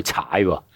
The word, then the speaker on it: CAAI: 你哋嗰间公司就有个名好过人，叫踩，不过系英文嚟嘅，C A A I，你教咗个发音啦。